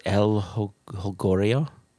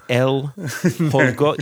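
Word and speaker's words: El Jolgorio: But it's an El Junglorio. – That's not how you pronounce the name, but we'll leave that in there. – How would you pronounce it? – I think it's El Holgorio.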